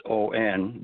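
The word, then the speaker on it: on (恩): It said in Japanese, there's a word called on. It's O-N, but it's pronounced on.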